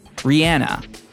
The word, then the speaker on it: Rihanna: Rihanna, and yeah, that is how you say her name.